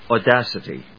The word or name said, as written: audacity